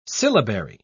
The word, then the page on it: syllabary: British English